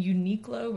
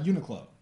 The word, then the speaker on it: Uniqlo: And Uniqlo, which is this clothing brand. So they'll sell some of... Is that how you pronounce it? – Yes. – It's not Uniqlo.